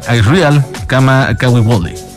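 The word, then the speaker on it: Israel Kamakawiwole (Israel Kamakawiwoʻole): Israel Kamakawiwole.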